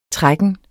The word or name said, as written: trækken